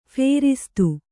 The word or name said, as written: ಫೇರಿಸ್ತು